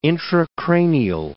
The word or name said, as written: intracranial